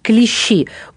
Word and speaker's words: клещи: И тема нашего сегодняшнего заседания – клещи. Ударение на последний слог. Клещи.